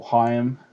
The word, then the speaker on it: Haim: If you like sort of Haim or Haim, as it's supposed to be pronounced,